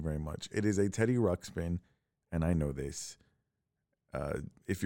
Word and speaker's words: Teddy Ruxpin: because we actually discussed later that I was pronouncing Teddy Ruxpin the wrong way. So I'm not an idiot. I know it's not called a Teddy Rupskin.